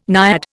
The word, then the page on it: naiad: Indian